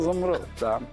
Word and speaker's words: Zambrotta: Oh, Zambrotta.